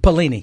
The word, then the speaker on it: Pelini: Belima. How do we pronounce his name? Belima? – Belima. Pelini is the coach at Nebraska. – Nebraska, right. – And talking about Pelini.